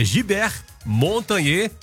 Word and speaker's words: Gilbert Montagnier: Aliás, Gilbert não, né? A pronúncia correta é nome francês, né? É Gilbert Montagnier, Juice for Tonight.